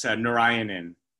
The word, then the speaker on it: Narayanan: It's Narayanan.